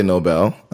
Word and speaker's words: Nobel: Now, is it Nobel? Noble? How do you say that? – I would say Nobel because it's going to be after Alfred Nobel.